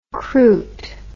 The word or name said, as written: cruit